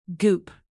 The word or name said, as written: goop